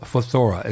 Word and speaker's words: phthora: It's pronounced phthora. It's spelled P-H-T-H-O-R-A, phthora.